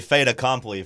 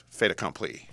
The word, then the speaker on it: fait accompli: I messed it up. What was it? – It's fait accompli.